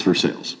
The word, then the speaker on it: Versailles: You know, instead of Versailles, it's Versailles. It's true.